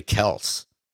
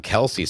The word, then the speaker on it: Kelce: We've been pronouncing it like Travis Kelce. It's actually Kels everybody's been calling him Kelsey so long, him and his brother, that they just that's it.